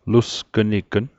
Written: lusgnign

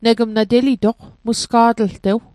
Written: telitoq

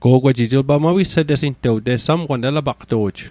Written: mawisetesing

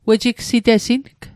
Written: wejigsitesing

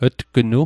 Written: tgnu

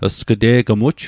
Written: sg'te'gmuj

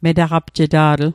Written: metaqapjeta'tl

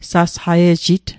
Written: sasqaiejit

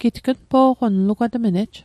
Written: npo'qon

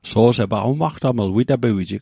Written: umaqtaml

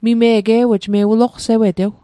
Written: mime'g

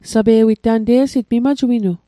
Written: sape'wit

